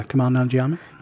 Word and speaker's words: Kumail Nanjiani: Like legitimately as Kumail Nanjiani, is that how you say it? – Yeah, Kumail Nanjiani.